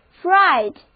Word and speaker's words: freight: 提到运费啊，我们顺道认识另外一个意思也是运费的单词，就是 freight。 F-R-E-I-G-H-T Freight 字母组合 G-H 在这里不发音 Freight。